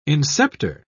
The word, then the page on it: inceptor: British English